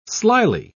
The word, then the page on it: slily: British English